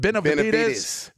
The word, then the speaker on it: Benavidez: Benavidez.